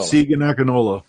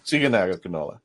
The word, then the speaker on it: Segun Akinola: Segun Akinola. – Segun Akinola.